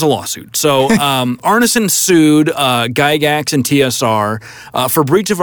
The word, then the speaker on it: Gygax: The original D&D system, I think everybody agrees, was jointly developed by two guys named Gary, I don't know how to pronounce his last name. Gygax.